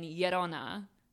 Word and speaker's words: Llorona: It's a double L at the beginning, so it's a Y sound instead of an L. So it's La, meaning the, and Llorona, which means Llorona.